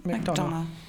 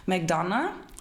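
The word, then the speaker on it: McDonagh: Donno, Donno, Donno, Donno, Donno, Donna, ja, aber das spricht man so auf, oder? Ja, McDonough. – McDonough, auch anders geschrieben, als es klingt, aber gut.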